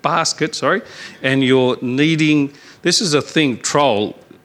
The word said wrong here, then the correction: troughs: Is that how you pronounce that word, troll? What it is, troughs, yeah, okay.